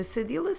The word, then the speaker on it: cedilha: C-E-D-I-L-H-A. Cedilha. And we will use it only to soften the C when it's followed by the vowels A, O, U.